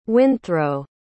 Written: windthrow

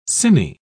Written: cine

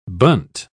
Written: bunt

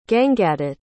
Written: gangadutt

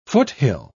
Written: foothill

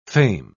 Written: fame

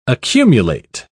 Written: accumulate

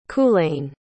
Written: culain

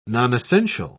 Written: nonessential

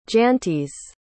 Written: jantis

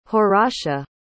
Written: horatia

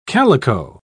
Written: calico

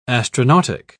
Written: astronautic

